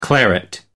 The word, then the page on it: claret: American English